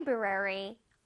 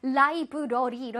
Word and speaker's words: library: 우리 라이브러리라고 하잖아. 근데 영어로도 라이브러리 이럴까요? No, no, no. library라고 해야 돼요.